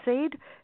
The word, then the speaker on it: Saad: But the character that fascinated me most was, I don't have, it's pronounced Saad, SA-AD.